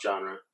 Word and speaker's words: genre: How do you say that? Genre? – Genre. It's genre.